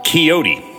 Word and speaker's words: Caioti: When chef slash owner Ed LaDou opened, oh, for fuck's sake, Chow T. Coyote C A I O T I. Chowdy Pizza Cafe. Oh, pronounced Coyote.